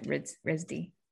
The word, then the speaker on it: RISD: Arts at RISD.